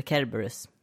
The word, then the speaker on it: Kerberus: Cerberus, eller Kerberus. Det kanske uttalas med ett hårt K.